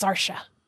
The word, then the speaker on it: Saoirse: It looks like if you were to pronounce it just with like American alphabet, it looks like it would be Sarose or Sarouse, but it's pronounced Saoirse.